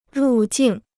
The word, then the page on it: 入境: rù jìng